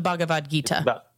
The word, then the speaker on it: Bhagavad Gita: The Bhagavad Gita.